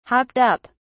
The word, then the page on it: hopped up: British English